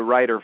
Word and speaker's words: Writer: I literally was born into a very, very wealthy Denver family, the Writer family, and my name is spelled W-R-I-T-E-R, so people think I'm a writer or an author, but I'm just by name.